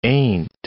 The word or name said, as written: ain't